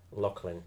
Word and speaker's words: Loughlin: Pronouncing your second name is Loughlin? – Loughlin, technically. – Loughlin, right, okay. – But, like, you know, I've had every variation. A lot of people tend to say Loughlin.